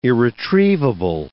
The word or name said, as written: irretrievable